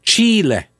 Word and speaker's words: Chile: Is that Monica's telling me on the Twitter that it's pronounced Chile. Le. L-E-H. Chile.